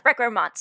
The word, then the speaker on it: recrement: I chose a word that sounded French, but I am not well trained in French or French pronunciation. So I say recrement or recrement. – Yeah, that sounds right. I just finished my French class this summer, so that sounds, recrement sounds right to me.